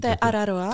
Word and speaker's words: Te Araroa: Kdy ty jsi šla tu Te Araroa, nebo já to nemůžu furt vyslovit. – Te Araroa, je to.